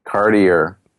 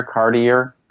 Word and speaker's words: Cartier: Cartier. – What? Cartier? – It's like the jeweler Cartier. – Okay. – It's Cartier, but I'm not going to pronounce it like some fancy French person.